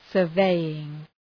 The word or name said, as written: surveying